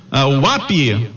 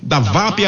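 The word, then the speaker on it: WAP: Um prêmio, uma furadeira de impacto da UAP, da VAP é a pronúncia correta, para quem acertar o campeão.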